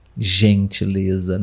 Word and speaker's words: gentileza: Então, mas com a vogal E, o som é gen, gentileza, né? O Z no final da, da sílaba tem o som de Z, de fato, Zé. né? Gentileza. Zá.